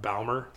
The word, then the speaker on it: Baumer: No, we're the only, we're as far as I know, we're the only, technically, our name is pronounced Baumer. It's B-A-U-M-E-R, and like...